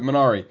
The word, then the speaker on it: Minari: She was also nominated for Best Supporting Actress for her performance in Marini. Did I say that right? Damn it. I always go back to it. Minari.